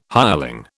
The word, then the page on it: hireling: British English